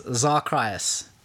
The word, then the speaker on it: XarCrius: And you can find me everywhere on the internet, Twitter, Xbox, Mixer, as Zarcrius, which is Xarcrius.